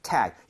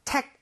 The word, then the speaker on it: tag: So, tag. 얘는 tag 아닙니다. G는 CK라는 스펠링보다 부드러워요. – 그쵸. – 그렇기 때문에 tag 하지 마시고요. tag, Tag. – tag. 이런 느낌으로 부드럽게 한번 해보세요.